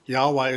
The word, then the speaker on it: Yahweh: So apologies to the Jehovah's Witnesses who met yesterday, not Jehovah. We don't even know actually how to pronounce God's name, YHWH, no vowels. So Yahweh is the best that we come up with there.